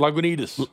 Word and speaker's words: Lagunitas: And then we had the Lagunitas. Is that how you pronounce it? – Lagunitas.